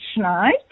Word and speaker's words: Schneid: So my surname is pronounced Schneid, but close.